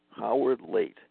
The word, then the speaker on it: Leight: Howard Late. It's spelled L-E-I-G-H-T. Probably a French name.